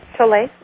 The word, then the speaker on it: Tolle: When you're fully just there, I love Eckhart Tolle. I don't know how to say his last name. Is it Toll or Tolle? – Tolle. – Yeah, Tolle.